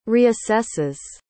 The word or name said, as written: reassesses